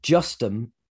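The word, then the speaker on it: Justham: Now, Justum is spelt how it sounds. It's just ham.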